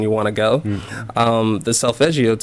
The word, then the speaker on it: Solfeggio: Solfeggio. That's how I pronounce it. I'm pretty sure it's Italian. – Yeah. Solfeggio.